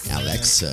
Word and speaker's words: Alexa: Ah, como é que pronuncia esse nome? Alexa. – Alexa.